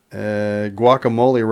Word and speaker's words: guacamole: guacamole rhymes with whack-a-mole.